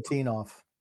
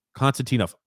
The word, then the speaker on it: Konstantinov: Konstantinov. – Konstantinov, plus 60.